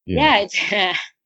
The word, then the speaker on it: Duterte: Duterte.